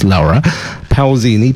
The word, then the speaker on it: Laura Pausini: You can just type Laura, as in the English spelling of Laura, but it's pronounced Laura Pausini, Pausini, and you can just type in her new song and have a look online and you can check it out there.